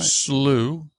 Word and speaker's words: slough: Although it, it may be pronounced maybe rather frozen. Oh, oh, slough. – Yeah, right. slough.